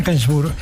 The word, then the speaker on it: Gainsbourg: Serge Gainsbourg, perfecto.